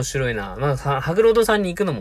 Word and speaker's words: 白鷺堂: とりあえず、今の心境あの、日曜日に行った、その白老堂さんの、白老堂だったかな。白鷺と書いて白老って読むんですよね、多分。白鷺堂と書いて白老堂だったと思うんですけど。